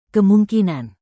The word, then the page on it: kemungkinan: indonesian